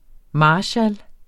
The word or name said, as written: MARSKALS